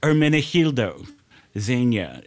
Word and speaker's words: Hermenegildo Zegna: Hermenegildo Zegna. – What was the name of the designer? Hermenegildo Zegna.